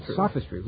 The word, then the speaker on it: sophistry: How do you pronounce this word? Sophistry? – Sophistry.